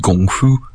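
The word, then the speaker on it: Gong Fu (功夫): And Kung Fu is really properly pronounced Gong Fu.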